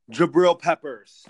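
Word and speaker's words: Jabrill Peppers: Yeah, I have for my breakout players on the Browns, Baker Mayfield, Kareem Hunt and the is it Jabril's Peppers? Because that's hard for me to pronounce his name. – Jabril Peppers.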